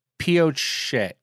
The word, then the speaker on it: Pioche: in our last That's My Bar, we covered uh, Eagle Valley Resort in what I said was Posh, Nevada. And that was supposed to be pronounced Pioche.